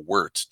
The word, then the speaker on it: wort: which in beer we call wort, W-O-R-T, but it's pronounced W-I-R-T.